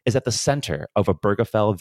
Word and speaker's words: Obergefell: For one thing, he knows that people will mispronounce his name. It's pronounced Obergefell.